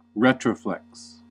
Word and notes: retroflex: American English

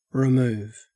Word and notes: remove: Australian English

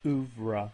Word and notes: oeuvre: American English

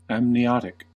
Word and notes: amniotic: American English

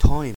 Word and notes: time: Australian English

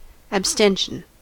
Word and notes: abstention: American English

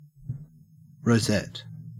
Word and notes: rosette: Australian English

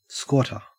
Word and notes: squatter: Australian English

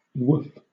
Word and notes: woof: British English

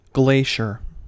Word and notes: glacier: American English

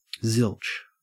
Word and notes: zilch: Australian English

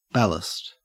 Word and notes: ballast: Australian English

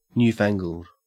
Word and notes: newfangled: Australian English

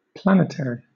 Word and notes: planetary: British English

